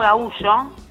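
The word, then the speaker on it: gaúcho: Y de hecho se llaman igual, no gaullo, se pronuncia distinto, pero se escribe igual, vos lo lees y es gaucho, pero se dicen gaullos.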